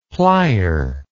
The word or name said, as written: plier